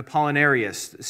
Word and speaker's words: Apollinarius: I should say Apollinaris and Apollinarius, same person, just two different ways to pronounce their name.